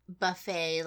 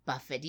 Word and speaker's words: buffet: So if you keep pronouncing buffet like Buffett, even though we've told you not to.